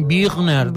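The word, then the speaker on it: Büchner: Lens, amb Z final, de George Buchner, amb la U amb dièresi, eh, segur que no ho he pronunciat bé perquè era alemany, Birner, deu ser, eh?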